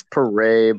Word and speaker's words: Pere: It's Pere, but no one gets it right, so it doesn't bother me at all. Most people say Pere.